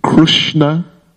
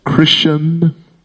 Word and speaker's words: Krishna: And then other parts of the country, some of them pronounce Krishna as Krushna. – В других частях страны Кришна произносит как Krushna. – Some of them pronounce as Krishan.